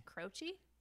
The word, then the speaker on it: Croce: His name is Jim Croce. – Croce? – Yes. – Oh, because he's Italian. So Croce, that's how you pronounce it.